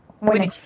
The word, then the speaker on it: Winnetou: A Native American, his sidekick, Native American, called Winnetou. Winnetou, maybe? T-O-U. So W-I-N-N-E-T-O-U. How would you say that, do you think? Winnetou? Winnetou? – Winnetou. Winnetou. – Yeah. – We're German. Winnetou. Anyway, well, he's never called a Native American, to be perfectly honest.